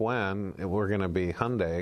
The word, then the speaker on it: Hyundai: Speaking of marketing people, we saw another change at General Motors of them stealing yet another executive away from Hyundai, or Hyundai.